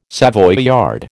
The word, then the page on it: Savoyard: American English